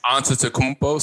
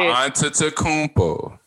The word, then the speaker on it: Antetokounmpo: Antetokounmpo. Say it with me, white man. – If you're gonna say Antetokounmpo.